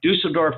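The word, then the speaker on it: Düsseldorf: Dusseldorf? – That's pretty good, yeah. – All right, Dusseldorf, Germany.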